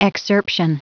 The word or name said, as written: excerption